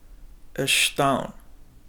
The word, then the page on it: ashtwaawin: Chisasibi